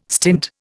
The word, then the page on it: stint: Indian